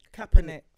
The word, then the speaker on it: Kaepernick: Kaepernick? – Kaepernick.